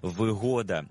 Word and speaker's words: вигода: Перше із них, що викликає у нас здивування – «вигода». «Вигода» має значення «зручність». Наприклад, «квартира з усіма вигодами», а не «вигодами». Це трискладовий іменник із наголосом на другому складі.